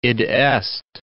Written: id est

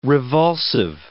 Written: revulsive

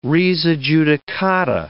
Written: res adjudicata

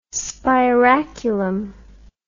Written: spiraculum

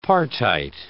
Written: partite

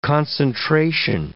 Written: concentration

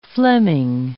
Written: Fleming

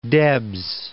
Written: Debs

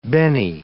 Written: benne

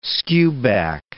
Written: skewback

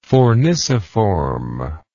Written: forniciform

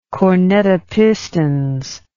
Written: cornet a pistons

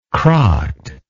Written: crocked

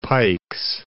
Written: Pikes Peak

